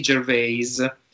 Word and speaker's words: Gervais: Ricky Gervais. Non, dirò mai, non, lo pronuncerò mai, mai più alla francese. Gervais.